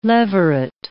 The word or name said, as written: levirate